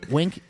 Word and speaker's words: Winc: Well, Wink first is spelled W-I-N-C.